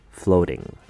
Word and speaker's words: floating: Again, don't be thrown off by the spelling. Don't worry about the letter A in this word and just say the word flow for the first syllable, like you're in a flow. Ting has your typical ing sound with a T in front, floating.